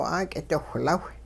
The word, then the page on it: 'aak'idiwhlaw: hupa